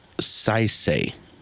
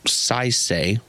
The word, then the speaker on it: Saisei: I'm going to butcher this name. Saisei. It's spelled S-A-I-S-E-I.